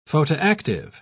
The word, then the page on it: photoactive: British English